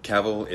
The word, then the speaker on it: Cavill: Cavill is pronounced like travel. Travel, Cavill.